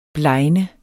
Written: blegne

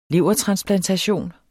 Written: levertransplantation

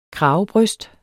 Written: kravebryst

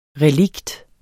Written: relikt

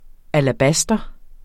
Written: alabast

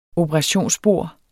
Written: operationsbord

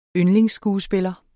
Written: yndlingsskuespiller

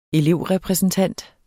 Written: elevrepræsentant

